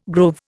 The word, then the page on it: grove: Indian